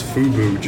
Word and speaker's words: FUBU: So what he did is he was eating the nachos and it got all over his FUBU jersey, right? Is that how you say it? – Yep.